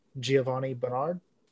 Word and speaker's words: Giovanni Bernard: Giovanni Bernard.